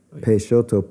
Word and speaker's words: Peixoto: Peixoto, P-E-I-X-O-T-O, mm-hmm.